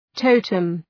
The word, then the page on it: totem: ‘təʋtəm